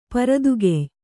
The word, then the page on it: ಪೂರಿತಗೆಯ್: pūritagey